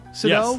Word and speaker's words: Sydow: Sydow. – Yes. – Right. Sydow.